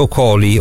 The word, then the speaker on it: Okoli: Senti Alen, la Gazzetta ipotizzava un interesse viola anche su Caleb Okoli, ora non so se la presentazione, la pronuncia sì, è corretta, sì, sì.